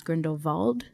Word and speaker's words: Grindelwald: I mean, it's spelled Grindelwald, but I think everyone pronounces it, or JK wants it pronounced Grindelwald. Just like vault.